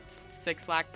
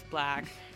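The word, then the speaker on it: 6LACK: On the 3rd, sorry, I'm just jumping back and forth, but on the 3rd we have Black, or Six Lack, pronounced Black,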